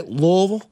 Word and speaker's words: Louisville: That's how you say it, by the way. Louisville.